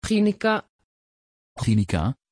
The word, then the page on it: Ginika: Dutch